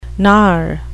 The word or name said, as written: gnar